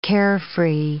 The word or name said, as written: carefree